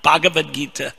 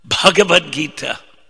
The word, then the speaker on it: Bhagavad Gita: Another thing, be careful when you pronounce ucharanam is pronunciation, dha, bha, gha, there are certain gh, bh, dh, bhagavatam, Bhagavad Gita. It's not Bhagavad Gita, Bhagavad Gita.